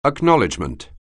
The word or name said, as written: acknowledg(e)